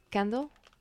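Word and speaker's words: Kandle: Pasa que suena como... Se escribe como... A ver. – ¿Candle? Sí, ¿no? Solo con K. – Claro, es como candle, pero con K. A ver. – Ajá. – Sí. Candle con K.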